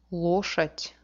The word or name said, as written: loshadi